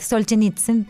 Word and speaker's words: Solzhenitsyn: Solzhenitsyn, premio Nobel de Literatura en el año 1970.